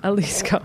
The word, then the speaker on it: Jalisco: In third place, we have Real Lasico. That's Perez. – Wait, what was that? I don't know. I said it wrong, I think. Real Alaska. – Jalisco? – Jalisco. There we go.